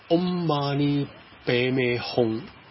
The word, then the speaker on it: Om Mani Padme Hum: Om Mani Padme Hum，咁 Om Mani Padme Hum 咧就藏文嘅发音，如果梵文咧就 Om Mani Padme Hum，其实佢系相差无几嘅，都唔争好多。